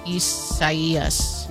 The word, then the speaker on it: Isaias: Isaias. Well, no, but it's probably the Spanish rendering of it, which if that's the case, then the I is pronounced like a E. – Isaias.